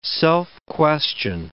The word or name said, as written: self-question